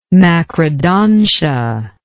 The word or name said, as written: macrodontia